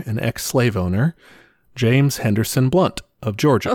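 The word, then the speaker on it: Blount: I think it's James Blunt also, but it's B-L-O-U-N-T and it's pronounced Blunt.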